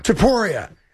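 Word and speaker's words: Topuria: Teluria, I can never say it right. – Teporia against Gaethje.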